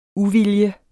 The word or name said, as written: uvilje